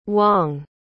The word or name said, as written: Wong